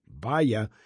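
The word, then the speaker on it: Bye-Ya: from the recording called Balance, Ben Winkleman's trio, and music by Thelonious Monk, Baya. It's spelled B-Y-E hyphen Y-A, Baya.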